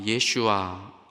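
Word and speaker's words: 예슈아 (ישוע): 그러나 또 한편 예수님의 본디 히브리 발음 예슈아 이거를 받아들인 메시아닉 주들 유대인들은 이렇게 주님을 높여요.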